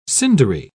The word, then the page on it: cindery: British English